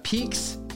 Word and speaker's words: Pique: Peaks Nundaka. Peak is spelled P-I-Q-U-E.